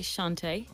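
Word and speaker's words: Chanté: Okay, I just want to say a quick hello this evening to, and I hope I'm not pronouncing your name incorrectly, is it Chante? Chante with a father on it, an accent. And how do you pronounce that name? – Yeah, I'd say Chante.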